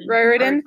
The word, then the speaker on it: Riordan: Riordan, who made the Percy Jackson series, and it has myths about the Greek gods.